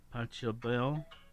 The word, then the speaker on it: Pachelbel: Pachelbel. It's spelled, I'll help you here, P-A-C-H-E-L-B-E-L.